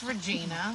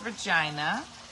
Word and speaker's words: Regina: It's Regina. – It's Regina. And they just came out with a new slogan. They said, come visit Regina. – Oh, yeah. – The name that rhymes with fun.